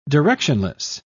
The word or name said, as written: directionless